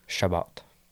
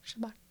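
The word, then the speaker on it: Chabot: Shabbat. – Shabbat.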